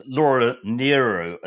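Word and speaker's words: Laura Nyro: Going back to our last podcast, Laura Nero, as we were told severely to pronounce her name, Dave Marsh and Laura Nero.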